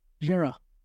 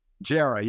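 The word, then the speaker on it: Jarry: Give me that pronunciation again. Jera. Jera, yeah, Jera. I always want to use the D.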